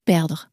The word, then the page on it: perdre: french